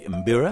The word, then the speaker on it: mbira: the mbira.